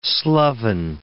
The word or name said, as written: sloven